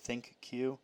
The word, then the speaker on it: ThinQ: Well, okay, so it's spelled thin with and then a capital Q, but they say you're supposed to say it ThinQ.